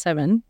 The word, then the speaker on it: Samhain: So Seven, it's pronounced Seven.